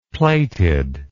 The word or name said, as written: plated